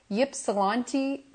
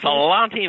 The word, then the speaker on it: Ypsilanti: It's Ypsilanti, Michigan. Am I pronouncing that correctly? Ypsilanti, Michigan. I believe you are correct.